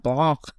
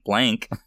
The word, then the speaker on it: Blanc: and Benoit Blanc. You almost want to say it like that, Blanc. – I feel like I have to now, because he corrected Jamie Lee Curtis when she called him Blank.